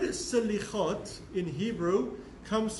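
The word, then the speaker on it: Selichot: And so I want to talk about the concept of Slichot, or in Ashkenazic pronunciation, we did a class a few weeks ago about the different forms of pronunciation, Slichas is the way Ashkenazic Jews pronounce it, or Slichot.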